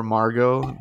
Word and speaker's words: Margot: Or Margot, not Margot, or Margot, like I've had her be called that, my dog. And I'm like, this T is not pronounced.